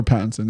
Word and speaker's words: Pattinson: Paddington? – No, I think it's Pattinson. – Or Pattinson, yeah.